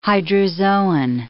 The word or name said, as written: hydrozoan